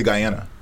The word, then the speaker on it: Guyana: How do you say it? – Fuck up the accent. I say Guyana.